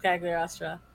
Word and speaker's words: Cagliostro: Listener, if you don't have Netflix to watch Castle of Cagliostro, message me on our Discord. – That's how you say it.